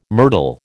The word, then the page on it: myrtle: American English